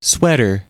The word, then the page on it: sweater: American English